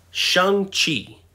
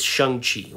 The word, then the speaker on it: Shang-Chi: Now you're saying, Brent, what is Shang-Chi? Well, that's how Kevin Feige pronounced it. Um, all this time, I thought it was pronounced Shang-Chi because it's spelled S H A N G C H I.